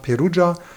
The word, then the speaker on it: Perugia: Also man fahre nach Perugia, so wird es richtig ausgesprochen, und dort findet man Werke von ihm.